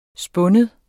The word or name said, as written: spind